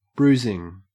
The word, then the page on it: bruising: Australian English